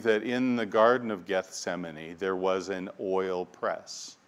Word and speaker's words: Gethsemane: If we were to take the Hebrew words for, oil, and press, and put them together, we would pronounce them, in English, we would say Gethsemane. Gethsemane.